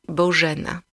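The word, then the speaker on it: Bożena: Surmonté d'un signe, il devient la chointante sonore Je. Dur si ce signe est un point. – Bojena.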